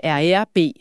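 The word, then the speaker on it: RER B: Den primære rute er jo med RERB, fransk udtalen.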